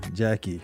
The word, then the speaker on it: Gyakie: Ghana's very own Jackie. – Wow, Jackie. – Yeah. – G-Y-A-K-I-E.